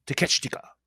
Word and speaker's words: Takeshita: and it's spelled differently than the way it's pronounced. Takeshita. Yes.